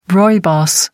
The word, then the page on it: rooibos: American English